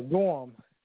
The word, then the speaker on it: Gorham: Gorham. – How do you spell that? – G-O-R-H-A-M. – H-A-M.